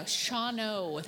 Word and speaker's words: Shawano: I am pronouncing it correctly, just so you know. Shawano, that's how you pronounce it.